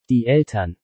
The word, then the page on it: Die Eltern: German